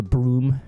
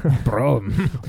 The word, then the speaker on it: Brougham: Brougham. – Brougham. – Broham, if you will. – I prefer Broham sandwich. – Yeah. – I believe that technically it's a Scottish word, and it should be pronounced broom, but I don't know.